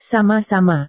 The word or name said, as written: sama-sama